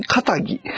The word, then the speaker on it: 気質: 職人かたぎ。職人気質って書いて、職人かたぎって読むの、これ。